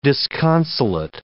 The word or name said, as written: disconsolate